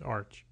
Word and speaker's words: ArCH: And it's just so people know, it's spelled capital A, lowercase r, capital C, capital H. – That's right. – And it's pronounced arch.